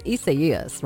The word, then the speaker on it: Isaias: I know the clouds that we're seeing kind of make people a little nervous with Isaias, or well, I think it's Isaias, right? That's how you say it.